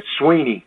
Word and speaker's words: Swinney: And, I get all kind of pronunciations of that, and it's Sweeney, as in just like E-E.